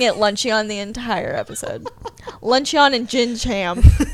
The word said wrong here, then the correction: gingham: What's the name of it? – Gingham. – Gingham. Mm-hmm.